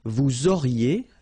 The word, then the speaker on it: vous auriez: Vous auriez, liaison here, and then EZ will go like E.